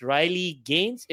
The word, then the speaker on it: Riley Gaines: Riley Gaines, if I'm pronouncing her name correctly? – Yeah.